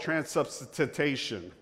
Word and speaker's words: transubstantiation: And I might butcher this word, but I'm going to go ahead and try it anyway. They call that transubstantiation. Did I say that right? There you go. Thank you, David. Transubstantiation.